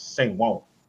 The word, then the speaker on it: San Juan: It's San Juan. You gotta say it like that.